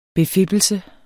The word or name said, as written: befippelse